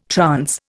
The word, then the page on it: trance: Indian